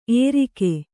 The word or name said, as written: ಏರಿಕೆ